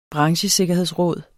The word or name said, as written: branchesikkerhedsråd